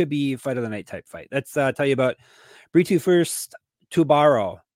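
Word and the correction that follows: Tubarão: Am I saying it wrong? It's a shark. – Tubau. – Oh, that's right. It's Brazilian. Tubau. They have a weird relationship with R's in Brazil.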